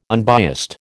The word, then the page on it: unbiased: American English